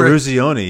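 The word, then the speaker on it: Eruzione: You get to, I mean, I remember that you reached Mike Eros. Oh God, I'm not going to be able to say his name. – Mike Ruzioni, yeah.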